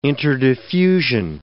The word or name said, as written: interdiffusion